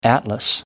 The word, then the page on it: atlas: American English